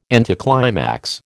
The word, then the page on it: anticlimax: American English